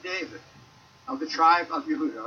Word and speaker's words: Yehuda: "in Yehuda," which is how you say Judah.